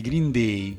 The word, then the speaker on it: Green Day: I Green Day, sentite la pronuncia è Day. Green Day.